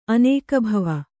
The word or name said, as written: ಅನೇಕಭವ